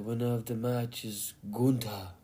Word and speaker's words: Gunther: Well, Gunther is how he pronounces it.